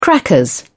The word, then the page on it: crackers: British English